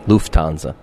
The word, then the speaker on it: Lufthansa: Yes, that was Lufthansa. How do you say it? – Lufthansa. – Yeah, exactly. That's what I said.